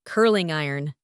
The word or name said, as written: curling iron